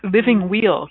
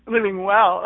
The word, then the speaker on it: Living Weal: It's called Living Wheel, correct? – Living Well, uh-huh. It's spelled Well, but I always say wheel because it's spelled. – I know. Well, that's actually like the traditional pronunciation, and me being the unconventional gal that I am, I actually pronounce it well.